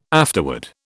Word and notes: afterword: British English

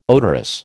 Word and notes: odorous: American English

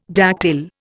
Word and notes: dactyl: Indian

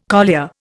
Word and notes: collier: Indian

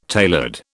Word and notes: tailored: British English

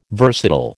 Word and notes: versatile: American English

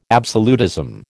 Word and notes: absolutism: American English

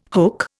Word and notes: hook: Indian

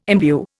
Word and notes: imbue: Indian

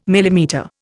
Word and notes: millimetre: Indian

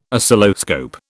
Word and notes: Oscilloscope: British English

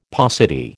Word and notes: paucity: American English

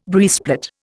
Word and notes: breastplate: Indian

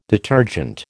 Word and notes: detergent: American English

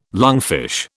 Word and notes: lungfish: British English

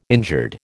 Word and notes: injured: American English

